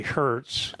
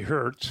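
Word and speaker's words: Heuertz: I'm going to say Hertz. – Okay. – It's H-E-U-E-R-T-Z. – Okay. – Okay. So let's say Hertz.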